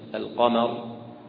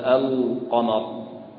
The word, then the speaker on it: القمر: كذلك يراعي ترقيق هذه اللام، يراعي ترقيق هذه اللام، فعندما ينطق بكلمة القمر لا يفخم اللام، لا يقول القمر لأجل أن القاف مفخمة، ولا يقول الخبير لأن الخاء مفخمة، طيب لكن يقرأها هكذا الخبير القمر كما يقرأ كلمة الجبال، واضح؟